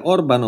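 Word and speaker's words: Orbán: Orban Orban, in ungherese le parole sono tutte accentate sulla prima vocale è che l'accento acuto indica che la vocale è lunga anziché corta.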